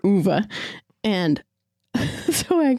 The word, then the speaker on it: Ove: Like, you guys haven't heard it yet, but we'll talk about a man called Uva. Apparently, that's how you say it. Mm-hmm. – Or I'll sit here and say a man called Ove.